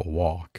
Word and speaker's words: walk: 工作是一个小口叫 work，那走路呢，圆口 walk，一定要注意。